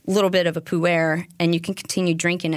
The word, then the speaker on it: pu-erh: Well, I kept mispronouncing it until my right-hand tea guru, Morgan, was like, no, it's pu-erh.